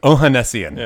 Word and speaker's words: Ohanessian: Am I saying that last name right? – No, you're not. – How do I say it? – You didn't even ask. It's Ohanessian. – Ohanessian.